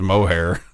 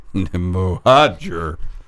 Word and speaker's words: Mohajer: But I texted my buddy this fall and I was like, how do you pronounce, I thought it was Mohair. Mohajer. Mohajer. Got it?